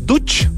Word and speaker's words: Duch: Y estamos en línea con Simón Duch de Casa o Casa Estudio.